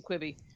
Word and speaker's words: Quibi: Am I saying it correct? – Oh, yeah. Quibi? – Yeah, you're saying it right.